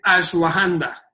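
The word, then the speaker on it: Ashwagandha: Mira, el nombre viene del sánscrito y justamente fue una revisión de cómo lo pronunciaban, o sea, lo puse ya en varios audios, y es Ashwahanda.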